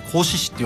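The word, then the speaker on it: 合志市: 合わせる志と書いて甲子市って読むんですけど、甲子市とか一緒になって地域から映像を作る、そういったクリエイターを育てていこうっていうのを始めたのが発端で、それが全国に広がってですね、今年 17 箇所で行われるんですね。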